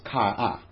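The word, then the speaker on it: chara (χαρά): And the Greek word is pronounced chara.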